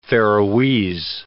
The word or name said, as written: Faeroese